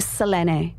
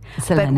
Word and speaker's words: Selene: Because it's pronounced differently in Greek, pronounced. – Well, I don't know that I'm going to pronounce it perfectly, but it would be Selene. – Selene. – But with a bit of a UK and Oz twang, we say Selene.